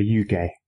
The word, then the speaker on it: Yuge: The two main guys, right, Tatsuya Uemura and Masahiro Yuge. Mm-hmm. Yuge? – I think you pronounced that perfectly.